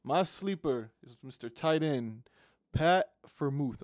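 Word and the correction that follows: Freiermuth: Did I say that right? Vermuth? – The Muth is loose? – The Muth is loose. – The Muth is loose. – Yeah, Pat Vermuth. That's my – that's Friermuth, but we'll go Muth.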